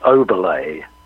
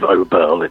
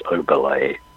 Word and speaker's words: Oberle: Yeah, it's Oberle. It's pronounced, there's a French acute accent on the last E. – Okay. – Which makes it an E. So instead of Oberle, it's Oberle. Yeah.